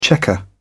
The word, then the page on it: checker: British English